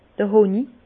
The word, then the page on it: d'r Honi: Bas Rhin; Herrlisheim